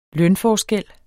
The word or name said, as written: lønforskel